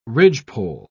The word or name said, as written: ridgepole